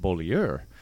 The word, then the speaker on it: Beaulieu: About got run out of town 2 weeks in because I went to Josh Beaulieu and said, how do you want your name pronounced? Is it Beaulieu? Because you would take a lot of pride in that. And he's like, well, actually, it's pronounced Beaulieu because it was French.